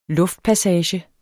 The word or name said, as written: luftpassage